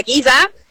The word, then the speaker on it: Giza: Claro, no, se pronuncia Giza, uh-huh. Que es la ciudad famosa por las pirámides.